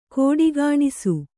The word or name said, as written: ಖೋಡಿಗಾಣಿಸು